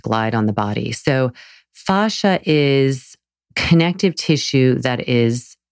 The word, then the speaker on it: fascia: So fascia, it's spelled F-A-S-C-I-A, fascia. Some people say fascia. How's your fascia today?